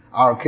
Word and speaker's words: arche: RK is spelled A-R-C-H-E. Genosos could be G-E-N-O-U-S-S-I or something. Genosos, S-U-S, sorry.